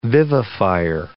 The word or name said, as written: vivifier